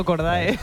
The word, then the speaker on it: Cordae: No, yo digo Cordae. – Entre Cordy y Cordae, por lo que sea, me parece más americano Cordy, aunque no se diga así.